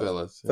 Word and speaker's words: Thurles: Thurles. – Yeah. Thurles.